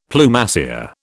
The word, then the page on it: Plumassier: British English